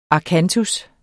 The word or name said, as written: akantus